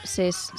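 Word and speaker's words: Sessue: ¿Cómo se pronuncia esto? Sesu.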